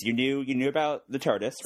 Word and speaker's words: TARDIS: Now, am I pronouncing this right? TARDIS? – TARDIS, that's TARDIS.